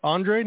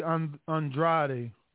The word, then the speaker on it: Andrade: Do they pronounce it Andre, not Andrade? – It's supposed to be Andrade, Demetrius Andrade. But then, no, Andrade. Andrade is Lombardo Andrade, who lost to Lucian Bute. It's Demetrius Andrade.